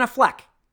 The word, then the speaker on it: Affleck: Ben Affleck.